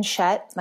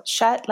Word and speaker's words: Schutt: My name's Megan Schutt, like shut the door. It's spelled kind of funny, but every year kids can't figure out how to say my name. So Schutt, like shut the door.